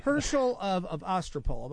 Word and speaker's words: Herschel of Ostropol: Now, Herschel of Ostropol. Am I pronouncing that correctly, Joel? – You are, yes.